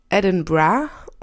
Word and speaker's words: Edinburgh: Und man schreibt es ja Edinburgh mit GH. Und ausgesprochen wird es dann aber irgendwie in Schottland Edinburgh und dann auch noch so mit dem gerollten R.